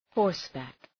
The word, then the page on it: horseback: ‘hɔ:rs,bæk